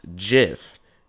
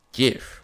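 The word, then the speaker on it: GIF: But, uh, no fly- it's pronounced Jiff, not gift.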